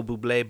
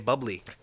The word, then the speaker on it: Bublé: I'm going to go with the Michael Bublé Bubbly commercial, where it's pronounced Bublé.